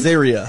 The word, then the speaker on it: Azaria: Hank Azaria, is that how you pronounce his last name? – Azaria.